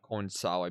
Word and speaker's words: Conceição: Conceição, I believe. – Conceição. I butchered that. I'm sorry to our Portuguese listeners.